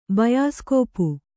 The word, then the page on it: ಬಯಸ್ಕೋಪು: bayaskōpu